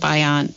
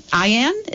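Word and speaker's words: Ayaan: I'm going to try to remind folks of that before the end of the show, too, that they look up Ayan. Ayan? Is that how you say her name? – It's not like Ayan Rand. It's got 4 letters, A-Y-A-N. – Okay. Yes, definitely. A-Y-A-N Hirsi, H-I-R-S-I, Ali, A-L-I.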